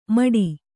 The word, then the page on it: ಠೂ: ṭhū